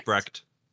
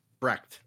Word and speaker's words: Brecht: Troubled by the death of his father in a car accident, outcast teenager Kale Brett? Brecht? I can't remember how they pronounced it. B-R-E-C. What? – Is Brecht. – Brecht. Okay.